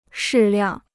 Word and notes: 适量: shì liàng

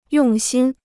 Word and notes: 用心: yòng xīn

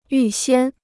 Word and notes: 预先: yù xiān

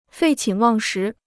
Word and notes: 废寝忘食: fèi qǐn wàng shí

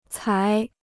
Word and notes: 才: cái

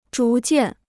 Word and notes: 逐渐: zhú jiàn

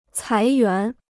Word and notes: 裁员: cái yuán

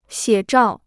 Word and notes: 写照: xiě zhào

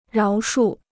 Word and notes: 饶恕: ráo shù

